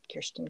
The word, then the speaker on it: Kirsten: The one where you'll actually find me participating the most is probably Instagram, and and I'm at Kirsten K.